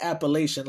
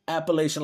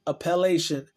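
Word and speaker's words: appellation: I think that's the correct word, but it's not Appalachian like Appalachian Mountains, but Appalachian like appellate court. So you say appellate with the I-O-N suffix. Appalachian, I believe it would be pronounced though.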